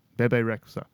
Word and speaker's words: Bebe Rexha: I don't know how to pronounce her name. Bebe Rexha. Is that it? Bebe Rexha? Bebe Rexha. B-E-B-E. And then the surname R-E-X-H-A.